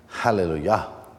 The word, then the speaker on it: Hallelujah: After these things, I heard something like a loud voice of a great multitude in heaven crying out, Hallelujah. You know, in the original, the emphasis is on the last, Yah, because that's the name of God. Hallelujah.